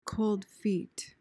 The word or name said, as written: cold feet